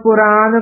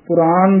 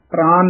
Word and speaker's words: पुराण: राम रहीम पुराण कुरान पुराण दे पत्ते अंकड़ नाल उच्चारण करना है प्राण नहीं कह देना पुराण कुरान